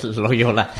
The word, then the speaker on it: Loyola: El hospital Loyola. – Ya. – En Estados Unidos. – Pero bueno, igual lo pronuncié yo mal. – Bueno, pues Loyola. – Así muy bien pronunciado, muy bien.